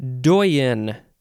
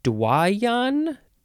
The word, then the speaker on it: doyen: Doyen is next. Doyen or Dwayan? Dwayan. Dwayan, is that how we're saying this? Dwayan, I think that's it. So Dwayan or Doyen, it is spelled, oh, this is the first D-O-Y word, D-O-Y-E-N, noun from 1670, 1A, the senior member of a body or group.